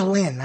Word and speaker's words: Lyn: C-A-T-H-E-R-I-N-E. – Oh, don't get me into the spelling, too. My name is Lynn. I spell it L-Y-N.